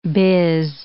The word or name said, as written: biz